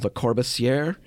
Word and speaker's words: Le Corbusier: You know, one of the key architects, I can't say his name, Le Corbusier? – Something like that, yeah.